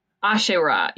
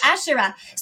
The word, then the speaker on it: Asherah: How do you pronounce that? – Asherah. Yes. – Asherah.